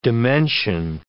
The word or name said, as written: dimension